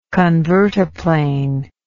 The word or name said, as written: convertiplane